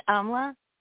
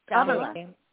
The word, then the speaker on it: Amla: My name's Amla. – Emma. – It's what? – Amla. Yes. How are you? – Spell it, please. – It's A-M-L-A.